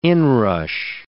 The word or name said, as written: inrush